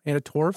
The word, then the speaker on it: Anna Torv: Anna Torv? – I think you nailed it.